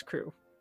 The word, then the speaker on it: Croup: CROUP. – Like it's not Croup, it's Crew.